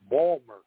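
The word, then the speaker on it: Baltimore: Well, I've been back, in the great state of Maryland a number of times before I realized that their largest city is called Balmer. – Yes, I've heard that. And I think it's spelled B-A-L-M-U-R, correct? – That's it, Balmer.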